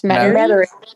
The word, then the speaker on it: Metairie: Did I say that right? – Metairie. It's Metairie.